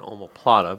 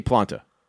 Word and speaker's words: omoplata: It's an omoplata, but yes. – I say planta. – You shouldn't.